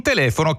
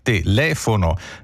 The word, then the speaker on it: telefono: In telefono cade su le, telefono, quindi non diciamo telefono come Stanley Olio.